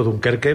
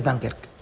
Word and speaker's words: Dunkerque: Yo digo Dunkerque, pero no es Dunkerque. Tú lo pronuncias bien. – Es Dunkerque. – Dunkerque, vale.